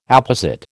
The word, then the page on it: apposite: American English